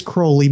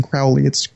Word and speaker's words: Crowley: So the Crowley deck, and just to be my pedantic ass self, it is Crowley because he is holy. It's in one of his little poems he wrote. People always pronounce his name Crowley.